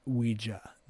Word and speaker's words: Ouija: He goes, it's pronounced Ouija, not Ouija. – By the way, drink right now, guys. – Yeah, right. It comes from we, meaning we and ja, the French and German words for yes, Ouija.